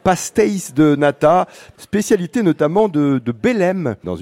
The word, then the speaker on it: pastéis de nata: Pastéis de nata.